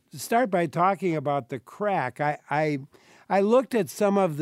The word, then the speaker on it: craic: And maybe you should mention first what the title means, The Crack. And it's spelled C-R-A-I-C, which I think is Gaelic.